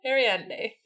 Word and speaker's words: Ariadne: When Arthur arrives at the warehouse the next day, Arianda and Eames... Ah, Josie is going to murder me. – Ariadne. No. There's an N.